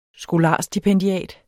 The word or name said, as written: skolarstipendiat